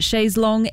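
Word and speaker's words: chaise longue: I will admit I never actually knew how to pronounce that correctly until this song came out. It's always confused me, but chaise long it is, and I now know how it is pronounced.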